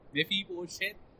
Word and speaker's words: Mephibosheth: It's Mephibosheth and David. Sorry, I mean we're pronouncing it wrong. Mephibosheth.